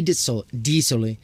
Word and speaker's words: Dit-Sully: So you don't pronounce the T of the dit. So Louis Dit-Sully. – Oh, D. Oh, okay. Louis Dit-Sully.